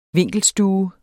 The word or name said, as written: vinkelstue